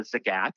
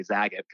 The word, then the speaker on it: Zagat: So I ended up being the editor for Zagat in Boston, or as I called it before I worked for them, Zagat.